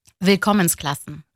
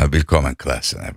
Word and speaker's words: Willkommensklassen: Anneniz emekli öğretmen ve uzun yıllar Almanya'ya yeni gelen çocukların eğitim hayatına uyum sağlamasına destek veren Willkommensklassen. – Evet, 60'lı yıllarda genel aileleri... – Tam ailelerin... telaffuzunu söyleyeyim bana. – Willkommensklassen. – Ha, Willkommensklassen. Benimki olmamış, evet. Babanız da akademisyen olarak Fransa'da doktorasını yaptıktan sonra bankacılık sektöründe çalışıyor.